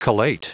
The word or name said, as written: collate